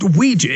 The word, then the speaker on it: Ouija: It's pronounced Ouija.